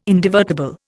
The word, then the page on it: indivertible: Indian